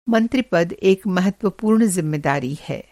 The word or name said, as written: मंत्रिपद